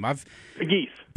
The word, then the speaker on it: Pegues: Is it Pegues? How do you say his name? – Pegues. – Pegues. – Like the bird.